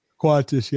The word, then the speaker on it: Qantas: Qantas, yeah.